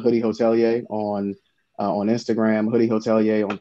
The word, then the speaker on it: hotelier: Hotelier.